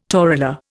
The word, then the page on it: torula: Indian